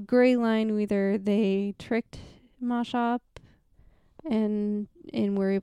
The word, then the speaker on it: Maushop: Sorry, I keep thinking you're saying Moshop, but you're not saying... – Well, it's spelled M-A-U-S-H-O-P. – Moshop. – Moshop.